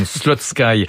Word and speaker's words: Slutsky: Alors vous le prononcez à la française, moi je le prononçais à l'anglo-saxonne, Slutsky.